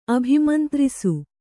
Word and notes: ಅಭಿಮಂತ್ರಿಸು: abhimantrisu

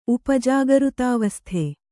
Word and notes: ಉಪಜಾಗೃತಾವಸ್ಥೆ: upa jagřtāvasthe

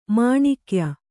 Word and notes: ಮೊಣಕೆಯ್: moṇa key

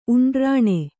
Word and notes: ಉಂಡ್ರಾಣೆ: uṇḍrāṇe

